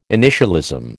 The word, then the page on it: initialism: American English